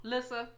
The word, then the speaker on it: Lyssa: Lyssa.